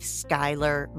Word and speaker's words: Skyler: Breaking without a G on through with Skyler Moon, S K Y L E R.